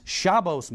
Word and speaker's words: Shabbos: Sabbath mode, also known as Shabbos mode, that's the Ashkenazi pronunciation,